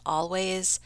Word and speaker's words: Alvvays: Okay, so this next band is called Always, but it's spelled A-L-V-V-A-Y-S, and it's titled Archie Marry Me, and it's from a Canadian indie pop band formed in 2011. The name is spelled that way because the actual band name Always, spelled the traditional way, was already taken.